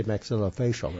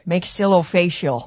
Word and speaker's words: maxillofacial: Did you say maxillofacial right? – Maxillofacial. – Maxillofacial. Do that again. – You're two for two. – Okay, maxillofacial.